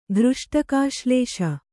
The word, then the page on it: ಘೃಷ್ಟಕಾಶ್ಲೇಷ: Kannada